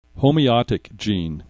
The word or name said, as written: homeotic gene